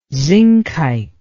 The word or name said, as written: zincite